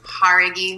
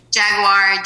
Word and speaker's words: Jauregui: So that pronunciation on that last name is Jauregui, Mike. You're going to get it one day. I really believe it. It's been Jaguar, Jauregui, and now it's today.